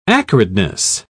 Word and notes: acridness: British English

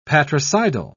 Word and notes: patricidal: British English

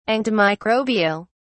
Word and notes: antimicrobial: British English

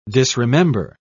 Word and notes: disremember: British English

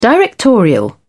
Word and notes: directorial: British English

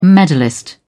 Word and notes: medalist: British English

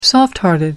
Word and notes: soft hearted: American English

